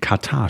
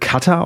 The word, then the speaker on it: Katar: Ist da ein Rundschreiben an die Redaktion rausgegangen, liebe Leute, ähm, falsch ausgesprochen, es wird Katar ausgesprochen und nicht Katar?